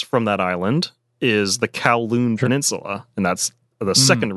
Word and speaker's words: Kowloon: So Kowloon is an anglicized pronunciation of the Cantonese phrase Kowloong, which literally means nine dragons.